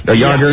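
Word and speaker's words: Jágr: It is on the CBS Sportsline website, and I can't pronounce most of these names, but it says Jaeger. It confirms... – Jaeger. – Jaeger. See, there you go.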